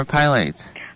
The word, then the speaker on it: Pilates: So first of all, is it pronounced Pilates or Pilates? – Pilates.